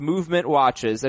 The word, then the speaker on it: MVMT Watches: MVMT Watches, that's pronounced Movement Watches, another awesome sponsor.